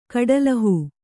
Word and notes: ಕಟ್ಟುಲುಹು: kaṭṭuluhu

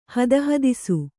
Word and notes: ಹದಹದಿಸು: kannada

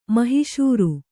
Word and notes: ಮಹಿಶೂರು: mahiśuru

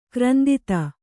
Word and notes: ಕ್ರಂದಿತ: krandita

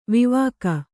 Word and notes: ವಿವಾಕ: vivāka

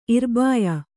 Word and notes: ಇರ್ಬಾಯ: irbāya

